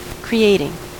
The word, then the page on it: creating: American English